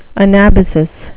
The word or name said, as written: anabasis